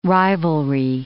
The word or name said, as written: rivalry